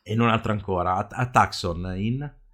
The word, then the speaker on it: Tucson: Perché Tucson. Se tu mi dici una città americana, io so dov'è. – Sì, sì. – Ma si dice Tucson, però. – Tucson. Oh. – Si scrive Tucson. – Sì.